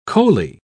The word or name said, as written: coley